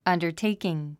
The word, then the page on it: undertaking: ʌ̀ndə r téikiŋ; アンダァテイキン